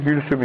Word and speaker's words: Külsüm: Demiş ki, galet Ümmü Külsüm, bu Ümmü Külsüm dedi ki, G harfi yoktur Arapçada, ince G yoktur. Bu Ümmü Külsüm dediğimiz Ümmü Külsüm'dür aslında. S'de peltek S'dir. Ümmü Külsüm. Ama biz Gülsüm yapmışız, biraz Türkçe'de telaffuz değişmiş.